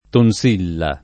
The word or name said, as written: tonsilla